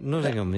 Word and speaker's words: Nottingham: Nottingham. – Nottingham, yeah, I get that right.